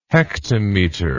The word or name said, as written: hektometer